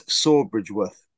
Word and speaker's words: Sawbridgeworth: but it's Sawbridgeworth. – Sawbridgeworth? – Yeah.